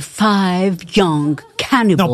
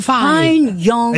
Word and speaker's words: Fine Young Cannibals: Yes, you have to say the five young cannibals. – Non, pas five, fine. – Ah, the fine young cannibals.